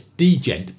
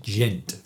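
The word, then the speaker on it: djent: And interestingly, the genre of music is called, well, it's degent, but I think you pronounce it gent, which I've never heard of.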